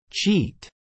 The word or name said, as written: cheat